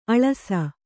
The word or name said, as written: ಅಳಸು